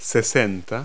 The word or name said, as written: sixty